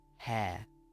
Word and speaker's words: hair: Be careful with the pronunciation of hair. Hair. Today we're talking about hair, getting your hair cut. So hair, which is the same sound as air, which you breathe, or fair.